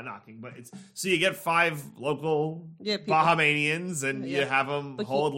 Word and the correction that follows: Bahamian: I think it's Bahamanian. – Bahamanian. – Bahamian. – Bahamian.